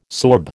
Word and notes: sorb: American English

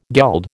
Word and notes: geld: American English

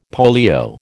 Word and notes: polio: American English

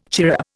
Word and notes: chirrup: Indian

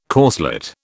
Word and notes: corslet: British English

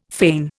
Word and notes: fane: Indian

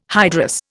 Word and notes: hydrous: Indian

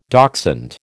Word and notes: dachshund: American English

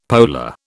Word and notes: polar: British English

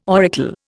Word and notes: auricle: Indian

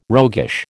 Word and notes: roguish: American English

